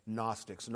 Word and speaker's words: Gnostic: John fought against the Gnostics. Gnostic begins with a silent G because it comes from the Greek word gnosis, meaning knowledge.